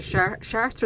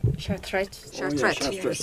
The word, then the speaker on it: Chartres: About your trip to Chartres, is it? – Chartres. – Sorry, my pronunciation isn't very good.